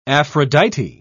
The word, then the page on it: aphrodite: British English